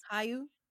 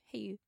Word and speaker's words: Hayu: Is that what it's pronounced? – Yeah. – Wow. – What did you think it was? – Hi You. Who You? Who You? – It actually says H-A-Y-U. Hey You.